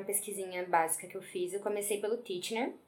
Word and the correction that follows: Titchener: é Titchener mesmo que fala? Titchener. É, né? – Mas...